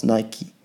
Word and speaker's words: Nike: Aber es ist tatsächlich richtig so, dass man, also man sagt nicht Bikey, sondern Bike, aber man ist hier nicht in der Rechtschreibung, sondern man ist hier bei einem Namen und der Name ist einfach Nike. Also so spricht man das richtig aus, Nike.